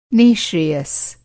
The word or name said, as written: नि:श्रेयस